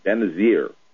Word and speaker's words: Benazir: Let me help you out with the pronunciation of her first name. It's Benazir.